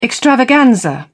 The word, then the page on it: extravaganza: British English